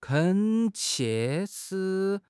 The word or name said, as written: 恳切